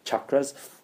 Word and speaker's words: chakras: And you know when you talk about chakras, which by the way Westerners mispronounce as chakras, that's the wrong pronunciation. It's cha, that's why it's ch, not sh. Chakras, but that's okay. Mispronounce Sanskrit if you want, it's not a big deal, but it is chakras.